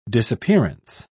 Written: disappearance

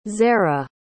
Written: zerah

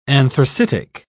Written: anthracitic

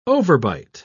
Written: overbite

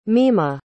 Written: mima

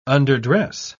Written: underdress

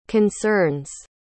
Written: concerns

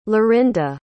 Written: lorinda

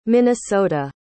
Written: minnesota

